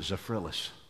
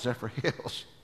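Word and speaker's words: Zephyrhills: And because I had Phyllis stuck in my brain, I pronounced it Zephyrillis. And it wasn't until I was in the grocery store one day and I picked up a bottle of water and I said, oh, that's Zephyr Hills. And then I was embarrassed for all the times that riding down the road by myself, I had pronounced it wrong.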